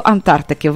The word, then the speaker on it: Антарктики: До Антарктики, так? Я правильно сказала? – Так, так. – До Антарктики.